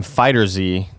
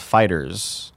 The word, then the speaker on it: FighterZ: Robert Gray says, during the list of upcoming games, Greg currently calls a new Dragon Ball Z game FighterZ. The game creators have said it's pronounced Fighters.